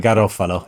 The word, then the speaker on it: Garofalo: And then I learned the rhythm of the surname. You never told me, you little bugger. – No, Garofalo. I can't remember why I did tell you in the end, but yeah, Garofalo. It's many syllables in there.